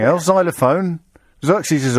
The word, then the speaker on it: Xerxes: Yeah, but it's Z. Xerxes. – Oh, is that That's how you pronounce X at the beginning of a word. Unless it's X-ray.